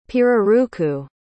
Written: pirarucu

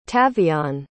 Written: tavion

